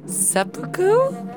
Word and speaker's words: seppuku: I would rather commit seppuku.